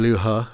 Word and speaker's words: Liu He: And Liu He from China.